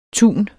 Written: tun

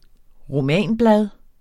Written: romanblad

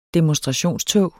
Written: demonstrationstog

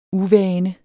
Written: uvane

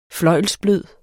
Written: fløjlsblød

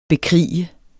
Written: bekrige

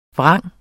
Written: vrang

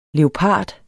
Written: leopard

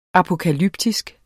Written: apokalyptisk

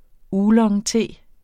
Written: oolongte

